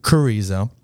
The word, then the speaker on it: Carrizo: He's in Chorizo Springs? – Carrizo. – Oh. Yeah. The H is silent.